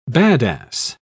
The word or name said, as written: badass